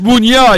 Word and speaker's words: Meunier: We got to work on the pronunciation, yeah. You got that New York pronunciation. – Of course. – It's Meunier, yo.